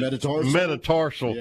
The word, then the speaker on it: metatarsal: He still has having trouble cutting on that – fifth how you pronounce it? – Metatarsal. – Yep.